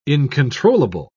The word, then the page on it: incontrollable: British English